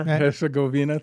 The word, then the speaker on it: Herzegovina: I don't know if I pronounced that correctly. – Herzegovina. – Herzegovina, thank you.